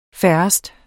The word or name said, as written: få